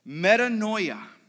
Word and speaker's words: metanoia: And that word repent in the Greek, okay, it's pronounced this way, and I won't butcher it. Metanoia, okay? Metanoia is repent in the Greek.